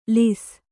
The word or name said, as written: ಲೇಸು